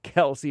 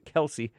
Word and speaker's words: Kelce: It's pronounced Kelce, even though everybody calls it Kelce. And they're too weak to correct them.